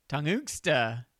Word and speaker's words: Tunguska: One of the best known recorded impacts in modern times was the Tunguska event, which occurred in Serbia, Russia in 1908.